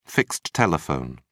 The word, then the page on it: fixed telephone: British English